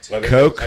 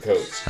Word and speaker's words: Koch: Is it Koch or Koch? – I think it's Koch. – Coke? – I think they pronounce it Coke.